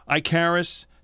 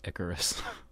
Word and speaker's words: Icarus: Icarus, Doctor Strange, Nick Fury, or Thor? – It's pronounced Icarus. – Oops. Icarus.